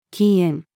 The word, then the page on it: 禁煙: female